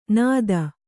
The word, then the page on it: ನೆತ್ತಿ: Kannada